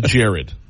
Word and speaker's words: Gerard: It G-E-R-A-R-D spells Gerard. But everybody over there calls it Jared, like the jeweler.